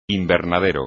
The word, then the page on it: invernadero: Spanish